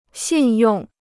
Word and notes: 信用: xìn yòng

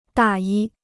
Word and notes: 大衣: dà yī

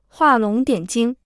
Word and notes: 画龙点睛: huà lóng diǎn jīng

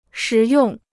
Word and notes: 实用: shí yòng

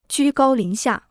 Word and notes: 居高临下: jū gāo lín xià

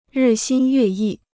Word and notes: 日新月异: rì xīn yuè yì

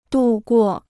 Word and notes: 渡过: dù guò

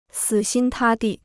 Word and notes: 死心塌地: sǐ xīn tā dì

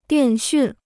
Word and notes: 电讯: diàn xùn

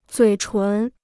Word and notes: 嘴唇: zuǐ chún

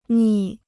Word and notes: 逆: nì

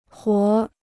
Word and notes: 活儿: huór

